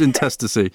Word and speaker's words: intestacy: Now, these are called the rules of intestacy, a great word, which I always have to double check how to pronounce that one. It's always a word that trips me up. When you see it written down, you're like, how do you pronounce that? But it is intestacy.